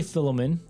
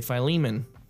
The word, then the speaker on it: Philemon: I say Philemon. – I say Philemon.